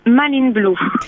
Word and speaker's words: Manline Blue: Ça se dit, ça se prononce même en français, c'est bien ça? – Oui, tout à fait. Bonjour à vous. – Bonjour. – Oui, ça se prononce Manline Blue.